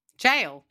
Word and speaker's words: gaol: I went to the old Adelaide Goal. – Goal? Jail? – Is it Goal? No, they say G-A-O-L. – Jail? – Is it pronounced jail? – G-A-O-L is jail.